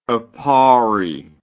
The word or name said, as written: Aparri